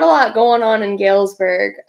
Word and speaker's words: Galesburg: Galesburg, Michigan, where you grew up? – Yeah.